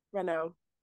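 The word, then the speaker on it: Renaux: Archie Renault. I don't know how you say his name. – Renault.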